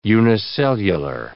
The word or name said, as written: unicellular